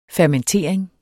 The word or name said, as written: fermentering